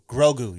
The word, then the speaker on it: Grogu: Is it Go-Gru? – You know, you said it right. Go-Gru? – That's what I'm saying. – Now. – Grogu. – Grogu, yes. Grogu.